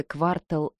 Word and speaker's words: Квартал: Район, где мы жили, назывался «Квартал Е», с ударением на первом слоге «Квартал».